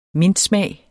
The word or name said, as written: mintsmag